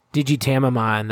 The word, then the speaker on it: Digitamamon: Digitamamon, that's what it is. – Oh, yeah, because Tamago is egg in Japanese. – Yes, Digitamamon.